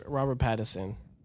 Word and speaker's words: Pattinson: They are getting your boy, Robert Patterson. How you say it? – Robert Pattinson to play a young Batman.